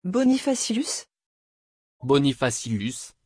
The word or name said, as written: bonifatius